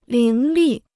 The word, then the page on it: 伶俐: líng lì